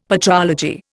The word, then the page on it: patrology: Indian